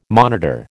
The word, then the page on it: monitor: American English